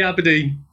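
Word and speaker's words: abrdn: By the way, how do you say Aberdeen now? Do you just still say Aberdeen? Or just say Aberdeen.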